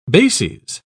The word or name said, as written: bases